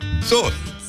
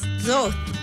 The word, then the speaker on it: Zoth: How do you say ball in Dene? Zoth. – Zoth. – Zoth. – Zoth. – Close. Zoth. – Zoth.